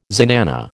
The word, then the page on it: zenana: American English